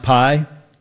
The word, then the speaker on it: PIE: PIE, is that pronounced PIE? – It is, yes.